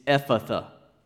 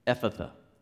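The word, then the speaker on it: Ephphatha: That word is Aramaic, and it's not easy to pronounce because it's got PH in there twice. Do you see that? It's Ephatha. Ephatha.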